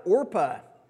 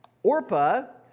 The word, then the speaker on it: Orpah: It was hard for her family members to pronounce the name Orpah, so they started calling her Oprah, which is why you join Oprah's book club and not Orpah's book club.